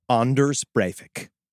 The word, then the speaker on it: Anders Breivik: But imagine if there was just one magical afternoon where Michael Jackson could have chosen Anders Breivik. Right, that's how you actually pronounce it.